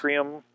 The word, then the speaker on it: Macrium: Macrium, it's just like it's spelled just like it sounds, M-A-C-R-I-U-M.